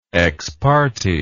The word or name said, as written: exparte